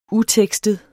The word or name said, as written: utekstet